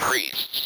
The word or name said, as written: priests